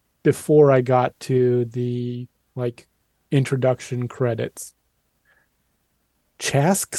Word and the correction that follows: Chaske: Maybe C-H-A-S-K-E Spencer. Chaske Spencer, it says here on Wikipedia. That's how I should pronounce it.